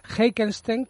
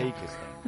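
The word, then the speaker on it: Heikensten: que no sé si lo habré pronunciado bien, he pronunciado bien Lars Heikenstein. – En Suecia. – Bueno, en Suecia. – Heikenstein. – Heikenstein, Bueno, más o menos.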